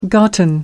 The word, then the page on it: gotten: American English